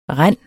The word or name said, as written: rend